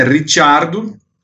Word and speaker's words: Ricciardo: O Abitebul também, dias atrás, falando que tem muito mais por vir com o Ricardo, um cara que pelo sobrenome dá para perceber, né? Ricciardo, fosse a pronúncia italiana, tem sangue italiano.